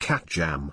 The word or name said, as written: catJAM